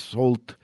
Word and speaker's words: Sault: Sault, se escribe S-A-U-L-T, se pronuncia Sault, y es para mi gusto uno de los proyectos más interesantes de los últimos años, muy, muy recomendable.